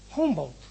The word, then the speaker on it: Humboldt: Humboldt, ¿no? Así se pronuncia.